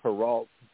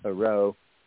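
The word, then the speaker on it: Hérault: Well, next week we'll be going to, it's spelled, it looks like Heralt, France, but I think it's pronounced Heraut, France, H-E-R-A-U-L-T,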